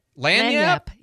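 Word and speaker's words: lagniappe: You say this word lanyap? Lanyap? – Yeah, lanyap.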